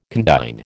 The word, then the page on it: condign: American English